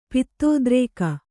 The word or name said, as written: ಪಿತ್ತೋದ್ರೇಕ